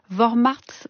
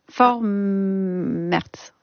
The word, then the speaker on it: Vormärz: Vous avez donné le terme allemand, Isabelle Garraud, c'est Vormärz. Vormärz.